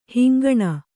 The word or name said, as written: ಹಿಂಗಣ